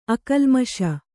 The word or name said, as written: ಅಕಲ್ಮಷ